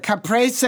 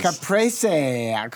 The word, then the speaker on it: Caprese: Now, let me get it correct. – Say it right. – Caprese. – There it is. – Caprese.